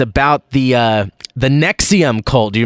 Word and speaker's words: NXIVM: It's like NXIVM. It's all like, it looks like kind of like Roman numerals, but it's I believe it's pronounced Nexium, and it's basically those celebrities.